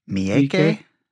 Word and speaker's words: Miike: How do you pronounce Takashi Miike? – M-I-I-K-E, yeah. – Miike.